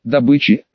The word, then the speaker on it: добычи: Так сказать, бомондом оказались местные вожаки, расселение, снабжение, добычи, опять с ударением на первый слог, и перемещение вместе с женами.